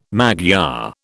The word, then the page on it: Magyar: British English